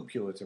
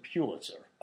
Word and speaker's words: Pulitzer: I remember him lashing out at somebody in class for saying Pulitzer. – Oh, it's Pulitzer? – That Pulitzer pronounced it Pulitzer. It's a Pulitzer Prize, and stop that very fancy talking.